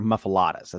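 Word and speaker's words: muffuletta: They're called muffalettas, but he said the OGs are muffalettas. That's how you pronounce them.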